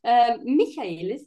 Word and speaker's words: Michaelis: Michaelis, if you want to try to pronounce my last name correctly.